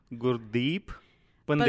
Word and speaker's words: Gurdeep Pandher: GURDEEP PANDHER. – PANDHER. Yeah. – PANDHER. I like holding on to the deer, which means the deer part means light, and GUR means teacher.